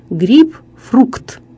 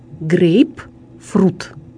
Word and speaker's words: грейпфрут: Ну а раз грейпфрут – это фрукт, то почему бы не произносить и не писать грейпфрукт? Однако, согласно литературному произношению и написанию, грейпфрут не содержит буквы «К» и произносится как грейпфрут.